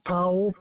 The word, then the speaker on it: Powell: Powell, P-O-W-E-L-L.